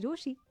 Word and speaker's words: Rochie: मेरा नाम है मिर्ची रोशी, प्यार से लोग मुझे ब्रोशी कहते हैं.